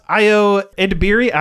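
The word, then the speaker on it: Ayo Edebiri: And last one is Io Edebiri.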